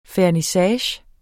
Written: fernissage

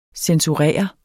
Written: censurere

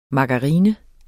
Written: margarine

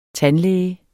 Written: tandlæge

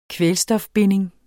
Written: kvælstofbinding

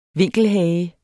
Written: vinkelhage

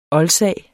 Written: oldsag

